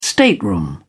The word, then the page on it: stateroom: British English